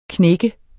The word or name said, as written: knæk